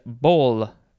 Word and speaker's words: boll: Next is bol, B-O-L-L. We also had B-O-L-E, but these are spelled differently. They pronounce the same.